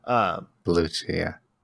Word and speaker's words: Bellucci: Bellucci, yeah.